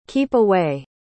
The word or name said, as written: keep-away